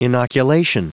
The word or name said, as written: inoculation